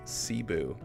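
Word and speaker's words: CBOO: or as I just found out, is pronounced Cebu.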